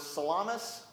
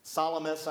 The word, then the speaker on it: Salamis: In the New Testament times, it's two major cities were Salamis. I keep calling it Salami. It's not pronounced that way. Salamis, I guess, which was the chief port city and commercial center.